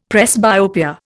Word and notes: presbyopia: Indian

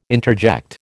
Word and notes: interject: American English

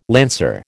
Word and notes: lancer: American English

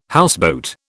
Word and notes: houseboat: British English

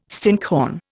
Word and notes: stinkhorn: Indian

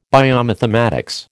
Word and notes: biomathematics: American English

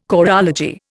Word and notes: choreology: Indian

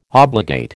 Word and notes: obligate: American English

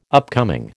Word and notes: upcoming: American English

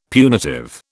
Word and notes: punitive: British English

